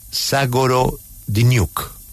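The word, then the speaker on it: Zagorodnyuk: Zagorodinyuk. Is that the correct pronunciation, Minister? Good morning. – Correct. Yes, it's correct pronunciation. Yes.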